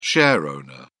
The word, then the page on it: shareowner: British English